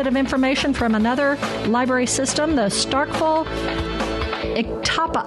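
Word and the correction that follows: Oktibbeha: I always say that wrong. How do you pronounce the county that this Starkville's in? – Iktibaha. – Iktibaha. There you go.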